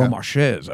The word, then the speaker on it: Beaumarchais: Beaumarchais, I believe it's pronounced.